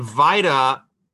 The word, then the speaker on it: VITA: The acronym for that is pronounced VITA like vitamin, not Vita like Velveeta cheese.